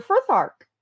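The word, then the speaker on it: Futhark: So when you said Norse, I went, Elder Frithark. Awesome. Yeah. And I'm probably mispronouncing it, but I was like, thank you, Harry Potter fan fiction. – Yeah, that sounds right.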